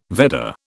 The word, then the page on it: Vedda: British English